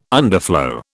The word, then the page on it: underflow: British English